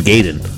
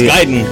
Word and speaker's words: Gaiden: But wait, here's Power Glove's theme of Ninja Gaiden, which is most commonly mispronounced. – It's usually Ninja Gaiden. – Yeah, which it ain't gay, motherfucker. It's Gaiden.